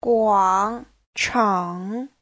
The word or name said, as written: guang3chang3